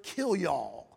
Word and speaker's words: kilyaw: The reins in the Hebrew comes from the Hebrew word kilyaw. It's spelled K-I-L-Y-A-W. Kilyaw, it's the mind.